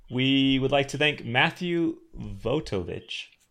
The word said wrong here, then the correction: Vojtovich: Oh, no, okay. Vojtovich. Matthew Vojtovich. – We need to apologize. We've been saying Matthew's name wrong for like 2 years.